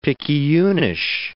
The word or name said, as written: picayunish